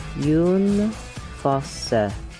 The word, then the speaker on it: Jon Fosse: Now, I would pronounce this John Fosse, but that's not correct, is it? – Jon Fosse. – Jon Fosse. I think that's how it's pronounced. – Jon Fosse. – Fosse. Okay, thank you.